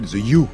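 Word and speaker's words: The HU: Il s'appelle The U, ça s'écrit H-U.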